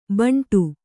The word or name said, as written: ಬಂಡು